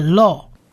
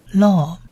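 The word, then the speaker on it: law (לא): 拉美这个字呢，发音上发了的音，而左上方呢有一个黑点，它是长母音 O，所以这个念 law， law， law。第二个字母呢是 Aleph，末音字母不发音，所以整体两个字母合起来就是念 law 的音， law， law，意思是不可，不可。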